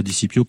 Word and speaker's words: Di Scipio: On dira Di Scipio, comme on dit d'ailleurs Scipion l'Africain. – Oui, c'est vrai d'ailleurs. – Qui est un nom de la même, qui s'écrit de la même façon.